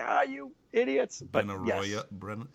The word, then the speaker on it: Benaroya: That's from, I'm going to say it wrong, I'm trying to remember what it is, Barona, or something like that, out in Seattle.